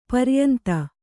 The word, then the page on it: ಪರ್ಯಂತ: paryanta